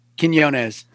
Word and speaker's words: Quinonez: Quinonez.